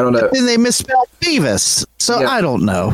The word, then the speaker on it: Behvis: And I think it's pronounced Beavis, B E H V I S.